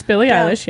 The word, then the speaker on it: Billie Eilish: It's Billie Eilish, yes.